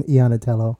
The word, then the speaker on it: Iannitello: We have Margaret and John Iannitello.